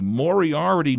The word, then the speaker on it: Moriarty: I hope I'm pronouncing it properly. Moriarty, New Mexico. Is that right? – Yes, that's correct.